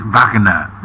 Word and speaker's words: Wagner: Si es una pronunciación correcta, ¿sabes cómo sería? Estoy escuchando a mi profesor de alemán, Fernando Curdín, es Wagner.